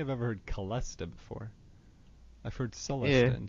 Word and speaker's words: celesta: Do you pronounce it celesta or celesta? – I pronounce it celesta. – What was that? Celesta? – Celesta. – Okay. – Like C-H, ch.